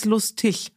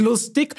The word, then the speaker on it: lustig: Und dann hat er gesagt, das heißt lustig. Habe ich gesagt, nein, das heißt lustig. – Und dann hat er gesagt, Mama, das G nach einem I wird ausgesprochen.